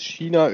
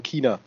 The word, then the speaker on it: China: Standardaussprache in Deutschland ist China, Österreich und Süddeutschland. Ah ne, Österreich und Süddeutschland sagen China. Und die Teil der deutschsprachigen Schweiz sagt China.